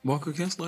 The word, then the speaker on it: Walker Kessler: Did I say his name right? You know that center for the Jazz? – Walker Kessler? I think you said it right.